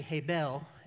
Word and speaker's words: Hebel: It's actually Hebel is how you pronounce that, and you pronounce it this way. So Hebel is Abel in Scripture.